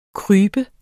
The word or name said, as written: krybe